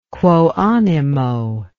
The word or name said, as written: quo animo